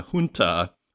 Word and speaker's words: junta: and it is with a, even though it's spelled with a J, it's pronounced H. I was looking it up today. So it's a junta, which is basically a military governance that often comes into being through a coup d'etat.